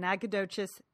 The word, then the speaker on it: Nacogdoches: She's going to an author festival in Nacogdoches, Texas.